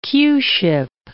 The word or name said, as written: Q-ship